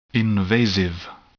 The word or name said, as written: invasive